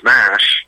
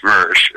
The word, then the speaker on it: SMERSH: This brings up over in James Bond land, you had Spectre and Smash, which is pronounced Smersh if you're British.